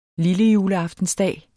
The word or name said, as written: lillejuleaftensdag